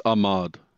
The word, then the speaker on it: Ahmaud: So this is the big one, which is the case with, how do you say his name? Ahmed Arbery? – I think it's Ahmad.